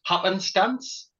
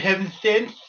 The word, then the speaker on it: Happenstance: Mr. Heavenstance. You should say it like that with a little bit of a lisp. That's how I like people to say it.